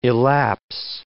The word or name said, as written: elapse